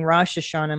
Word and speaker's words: Rosh Hashanah: You said Rosh Hashanah. Is that the right way to pronounce it? I've been saying Rosh Hashanah my whole life. – No, that's fine.